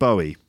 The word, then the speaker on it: Bowie: And it is pronounced Bowie, not Bowie or Bowie. Apparently David himself would refer to himself as Bowie. You might hear people say Bowie or even Bowie.